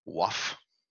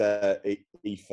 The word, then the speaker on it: Aoife: Yeah, I always say in my head, like, waff. – The waff. no, it's Aoife.